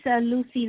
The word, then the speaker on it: Lucy: Okay, my name is Lucy Lush. It's spelled as L-U-C-Y, and my last name is Lush, L-U-S-H.